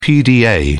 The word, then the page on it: pda: British English